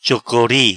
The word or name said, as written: Chocori